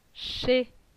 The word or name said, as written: Che